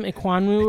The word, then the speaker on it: Ekwonu: Ekwonu, or whatever.